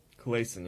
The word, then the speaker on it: Kalaisan: Kalaisan, yeah.